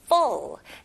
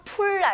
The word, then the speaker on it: full: The garbage can is full. 네, 가득 찬 거는 full. F 발음 명확히 해주시면서 풀 아닙니다. Full. The garbage can is full.